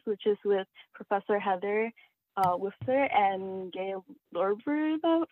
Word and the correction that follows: Lorberbaum: Sorry, I butchered that. But Lorberbaum, yes.